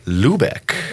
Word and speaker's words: Loobeek: Tonight is an ASID guest, Miss Carly Loobeek.